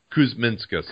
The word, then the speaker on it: Kuzminskas: Kuzminkas. – Yes. Thank you. – I butchered that, but when we get a pronunciation guide, we'll get it right. – Kuzminskas. – Kuzminskas. I believe that makes sense.